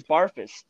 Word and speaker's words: Barfuss: Last name is Barfuss. – Okay, that's what I thought. – Just pronounced exactly how it's spelled.